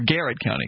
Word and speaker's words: Garrett: But first, I want to welcome Jared County's original hometown station, or Garrett County, excuse me. Garrett is the proper pronunciation.